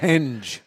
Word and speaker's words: henge: Henge. – I heard you talking in the ash holes about my pronunciation. – Hedge. You were saying hedge before. – Henge. – It's a henge.